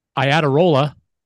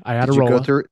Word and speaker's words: Iadarola: We have Steve Iadarola. That's how you say it, everybody. I had a roll.